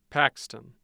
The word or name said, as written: paxton